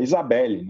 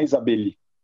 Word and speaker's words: Isabelle: E, e chama a atenção, tem uma história que eu ouvi, não sei se é verdade, não sei se é mentira, que o nome da Isabelle Fontana é Isabelle, não é Isabeli. E o Faustão é o cara que mais pronuncia esse I na história do Brasil.